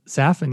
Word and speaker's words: Safin: That's Safin, yeah.